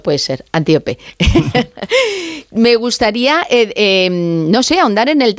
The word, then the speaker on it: Antíope: ahondas mucho en la relación de Teseo con Antílope. Pero lo he dicho mal el nombre, es Antíope.